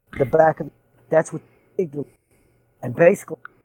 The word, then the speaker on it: amygdala: He notices that they remove a part of River's brain, and he mentions that they cut off her amalga. – Amygdala. – Oh, yeah, Amadillo. I forgot how to say it. you said it right.